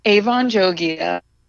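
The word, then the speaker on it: Avan Jogia: Oh, what I will say is I just watched a video of Avon Jogia, which I learned is how you pronounce his name, and that apparently a lot of people don't pronounce it correctly.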